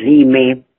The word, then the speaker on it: Lyme: Sí, primero que me gustaría que pudieras deletrear el nombre de la enfermedad, es LYME. – Sí, la enfermedad se llama Lyme, que se escribe L-Y-M-E.